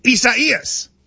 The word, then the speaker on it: Isaias: I-S-A-I-A-S. – And it's four syllables. I do know that. I-S-A-I-A-S. And it's like... – I-S-A-I-A-S. – Isaias. I think that's how it is. – No. – Yes, it is. – I think it's Isaias.